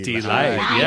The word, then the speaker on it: Deee-Lite: How do you say Dee-Lite? – Dee-Lite. Yeah. Dee-Lite.